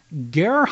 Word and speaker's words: Gahr: So Brett Barbecue, Brett Barbary, Long Beach, California native, we pulled his card here, a star at Gehr High School, I believe that's how you say it, G-A-H-R, in Cerritos, California.